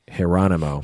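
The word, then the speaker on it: Jeronimo: His name is Geronimo Yanez. Mm-hmm. So think Geronimo, but with a J, pronounced Geronimo.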